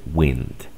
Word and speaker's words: wind: La excepción de esta regla que te acabo, o de este patrón, es esta. Viento se dice wind.